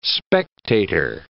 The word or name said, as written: spectator